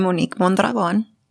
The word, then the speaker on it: Monique Mondragon: I'm Monique Mondragon.